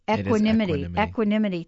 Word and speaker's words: equanimity: It's kind of like, and thank you, Lynn, for sending your email, because Lynn says, let me pronounce it, equa, not equal. It's pronounced equa, equanimity.